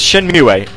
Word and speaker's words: Shenmue: Kaito's saying apparently it's pronounced Shenmue.